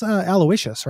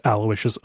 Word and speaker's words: Aloysius: Not famously, but on Abject Suffering, I mispronounced Aloysius as Aloysius because I had heard the name Aloysius, but I didn't know that that was how it was spelled. And I was just pronouncing it phonetically to, you know, to my mind, kind of over Romanizing like an Irish name.